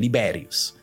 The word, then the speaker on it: Liberius: Sorry if I let's use the Latin uh, pronunciation, Liberius.